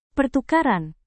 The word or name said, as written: pertukaran